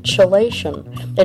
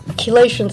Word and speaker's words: chelation: But the reason is that chelation is spelled C-H-E-L-A-T-I-O-N. So the C-H is very seldom. Can you think of any words that have a C-H that are pronounced with a K? – No.